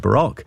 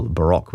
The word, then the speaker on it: Barok: they spell it B-A-R-O-K, NZ Baroque.